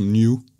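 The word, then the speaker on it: Nu: Det første, det er det græske N, og det øh, udtales åbenbart noget, noget, øh, som noget, der lyder som new. New på engelsk.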